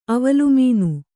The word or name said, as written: ಅವಲುಮೀನು